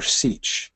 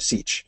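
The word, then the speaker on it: sietch: I think if it were simply democratic, it looks like the one syllable people have it. That's how I was taught. When I first read Dune, and I first read Dune in English class in 11th grade, I was taught Siege.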